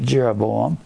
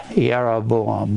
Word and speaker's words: Jeroboam: If you're speaking Hebrew, J's are not pronounced J's. They're usually pronounced Y, Jeroboam, but we're not going to get into that. We're just going to call it Jeroboam. That's what it looks like. There are no J's in the Hebrew.